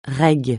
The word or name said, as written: reg